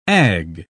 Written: ag

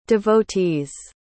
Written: devotees

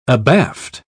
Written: abaft

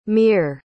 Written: meer

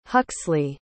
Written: Huxly

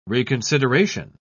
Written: reconsideration

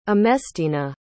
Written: emestina

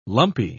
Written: lumpy

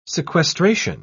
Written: sequestration

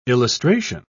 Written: illustration

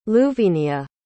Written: luvenia

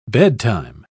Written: bedtime

